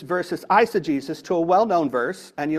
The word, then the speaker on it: eisegesis: There's something unpleasant called the eisegesis. And that's spelled E-I-S-E-G-E-S-I-S, which means to insert your modern beliefs into the scripture. E-I-S-E-G-E-S-I-S, eisegesis, is bad, okay?